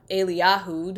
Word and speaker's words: Eliyahu: And it's about Elijah, or Eliyahu, depending on whether you want to use the English pronunciation or a Hebrew pronunciation.